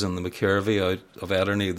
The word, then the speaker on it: Ederney: Ederney.